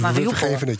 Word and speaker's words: Mariupol: En eh, eh, dat, dat zie je bij heel veel eh, Joden, maar ook gewoon niet-Joden in Mariupol. Zoals je het net uit, dus ik legde de klemtoon verkeerd. Hè? – Mariupol.